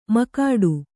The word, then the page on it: ಮಕಾಡು: makāḍu